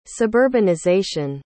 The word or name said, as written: suburbanization